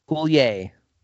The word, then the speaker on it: Coulier: Coulier.